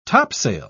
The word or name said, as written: topsail